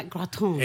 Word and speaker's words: gratin: Bueno, supongo que se pronuncia gratin. – Efectivamente, ¿no? En francés.